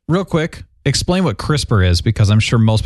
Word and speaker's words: CRISPR: It's an acronym, pronounced CRISPR. It stands for Clustered Regularly Interspaced Short Palindromic Repeats.